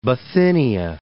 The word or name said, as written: Bithynia